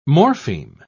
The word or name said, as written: morpheme